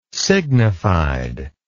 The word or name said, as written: signified